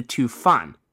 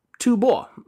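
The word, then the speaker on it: Tu Bo (吐蕃): However, friend of the show, Yuan Liu, pointed out to me a while back that the word in question, the one I'd been reading as Fan, can actually be pronounced as Bo, and in the case of the Tibetan kingdom, ought to. Tibet, Tu Bo.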